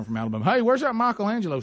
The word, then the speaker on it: Michelangelo: This is going to help Greg a little bit on the pronunciation of Angelo. Mm-hmm. It's say it like Michelob. – Michelangelo.